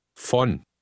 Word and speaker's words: Von: Von. Von. – Note that German V is pronounced F.